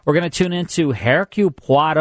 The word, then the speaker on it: Hercule Poirot: It's right. It's how you pronounce it. Hercule Poirot.